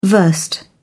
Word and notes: versed: British English

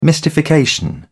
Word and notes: mystification: British English

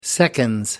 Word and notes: seconds: British English

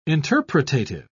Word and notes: interpretative: British English

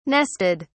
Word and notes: nested: British English

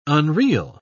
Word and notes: unreel: British English

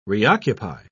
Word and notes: reoccupy: British English

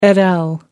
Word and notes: et al: American English

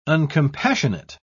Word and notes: uncompassionate: British English

